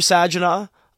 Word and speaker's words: Saginaw: Driving through Saginaw, uh, great city. Soft G, not a hard G.